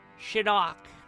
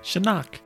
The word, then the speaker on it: Shinnok: You may call me Shinnok. – What? – Shinnok. – Shirok. – Shirok. – Shinnok. – So, wait, give me a history check. – And the accent is on the first syllable.